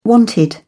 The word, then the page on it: wanted: British English